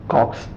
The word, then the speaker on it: Cox: And I would like to say that the people here, Consonetti, how do you pronounce this place? – Cox, just Cox.